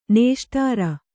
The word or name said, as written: ನೇಷ್ಟಾರ